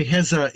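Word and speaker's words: Heza (غزة): It's When you say Heza, is that actually a Arabic pronunciation of Gaza? – Yes. – Gaza is how we say Gaza. Yeah, that's correct.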